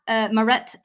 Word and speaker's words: Maret: So I've obviously been doing what every Englishman does and butchering the language, Maret, not Marit.